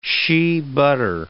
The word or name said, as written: shea butter